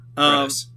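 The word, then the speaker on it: Qo'noS: which are based at Klingus instead of Konos. – Kronos. – What? – It's pronounced Kronos.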